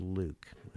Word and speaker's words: LIWC: And the computer program was called, we later called it Linguistic Inquiry and Word Count, LIWC, which we pronounce Luke. And I know it doesn't sound look like Luke, but it is.